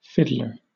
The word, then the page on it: fiddler: British English